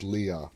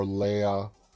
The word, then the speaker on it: Leah: I think the right way to pronounce it is Leah. Leah, I keep going to my Star Wars, and it's like Princess Leia. But it can be either Leah or Leia.